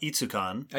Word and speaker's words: ETSUCon: ETSUCon, I think they call it Itsucon. – Yep. – If I read the pronunciation guide on the website correctly. We will both be presenting at Itsucon as representing the podcast and the Gray Fossil Site and Museum.